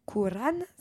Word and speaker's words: Coran: qui ont dit Coran si je me trompe pas, hein, j'ai sûrement une mauvaise euh, prononciation. Non, c'était très bien.